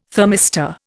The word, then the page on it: thermistor: Indian